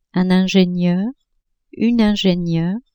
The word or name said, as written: ingenieur-ingenieur